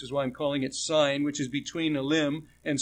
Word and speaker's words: Sin: I'm pronouncing it Sin just to make it's S-I-N, it's not the word sin, but it's the name of the place,